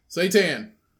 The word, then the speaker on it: seitan: Now, what's the pronunciation? – Seitan. Seitan. – I think it's satan. – No, seitan. – It has a little chick over the A. – That means it's say.